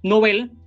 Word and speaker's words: Nobel: Recordemos que este apellido de origen sueco, que alude por supuesto a Alfred Nobel, lo adecuado es pronunciarlo como si fuera una palabra aguda, porque realmente lo es. En su lengua de origen, Nobel es una palabra aguda. Aunque la pronunciación Nobel, que está harto extendida, incluso entre personas cultas, recuerden que no se recomienda. Lo apropiado sería Premio Nobel de la Paz, Premio Nobel de Literatura, no Premio Nobel.